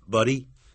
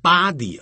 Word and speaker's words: buddy: buddy buddy 就是老兄 B-U-D-D-Y 可不要念成 body 啊 body 代表尸体或身体那是 B-O-D-Y